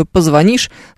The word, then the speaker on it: позвонишь: Да, объясняем раз и навсегда, что ударение в слове «позвонишь» ставится на букве «и». Так же, как, например, в слове «включишь», например.